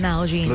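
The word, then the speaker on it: NewJeans: Y viene de parte de un lugar muy especial que se llama Now Jeans. – Now Jeans.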